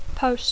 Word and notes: post: English

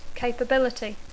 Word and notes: capability: English

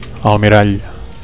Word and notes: almirall: Catalan

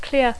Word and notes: clear: English

